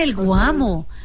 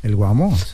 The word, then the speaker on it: el Guamo: Entonces, ¿qué pasa, el Guamo? Se dice así. – Chao, Ana. – Sí, claro, el Guamo.